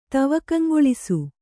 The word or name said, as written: ತವಕಂಗೊಳಿಸು